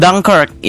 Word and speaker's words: Dunkirk: Dan Dunkirkwe ya tulisnya Dunkirkwe tapi kayaknya bacanya Dunkirk gitu.